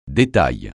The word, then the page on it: détail: francais